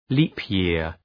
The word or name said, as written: leap-year